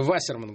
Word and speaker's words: Вассерман: Анатолий Вассерман, кстати, правильно Вассерман говорить, если вы не в курсе. – Как угодно. – У него немецкая фамилия, на первый слог там ударение.